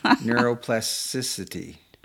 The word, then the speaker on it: neuroplasticity: You need to like saying it correctly, though. – I didn't say it right. – Plasticity. – Then I'm not going to say it anymore. – Oh, no. – Neuroplasticity. – Plasticity. – Plasticity. – There you go.